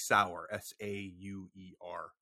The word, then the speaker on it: Sauer: It's spelled like Sauer, S-A-U-E-R.